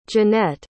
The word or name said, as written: jannette